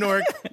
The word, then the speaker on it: Newark: I'm born and raised in Newark, and this is how it's supposed to be pronounced, N-O-R-K.